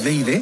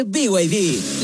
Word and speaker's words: BYD: ¿No es VID? – Nada que ver. Se escribe BID, pero se dice BYD.